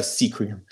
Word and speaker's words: Sequim: You probably think it's Sequim, S-E-Q-U-I-M. It's pronounced Sequim. – Or Sequim. – Yeah, Sea Cream.